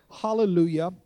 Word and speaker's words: hallelujah: Hallelujah. It has a soft J in the middle of it, if you didn't know that. Yah, hallelujah. But here's what hallelujah means.